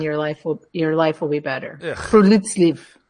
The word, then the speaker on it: friluftsliv: It's spelled F-R-I-L-U-F-T-S-L-I-V, but it's pronounced friluftsliv.